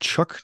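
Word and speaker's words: Chuuk: Truk was like, well, it's called Chuck now because it's probably closer to the native pronunciation.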